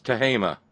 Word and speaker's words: Tehama: T-E-H-A-M-A. I guess it's Tehama. Or perhaps Smith. Yep, there it is, Tehama. That's a long A there in a place where a softened A ordinarily would exist.